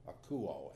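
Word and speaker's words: akuo (ἀκούω): We're talking about this idea of hear, akuo, A-K-U-O is the way it's pronounced.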